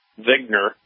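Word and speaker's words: Wigner: Vigner. It's actually like he just spelled with a W.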